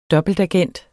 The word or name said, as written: dobbeltagent